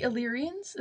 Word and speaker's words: Illyrians: I feel like the vibe I'm getting from this, like, the Illyrians, is that how you pronounce it? – Yeah.